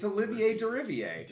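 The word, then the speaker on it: Olivier Deriviere: The music for Plague Tale: Requiem was composed by, I'm gonna butcher this, Olivier Derivier. – It's Olivier Derivier. – Olivier Derivier.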